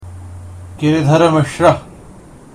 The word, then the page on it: Giridhar Mishra: Sanskrit